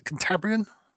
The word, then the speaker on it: Cantabrian: Is it Cantabrian? – Yes. – Yes. – Thank you. For some reason, I always overthink that one.